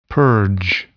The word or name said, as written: purge